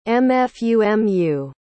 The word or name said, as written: mfumu